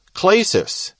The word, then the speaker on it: klesis: and the Greek participle klesis, K-L-E-S-I-S, again a long E there.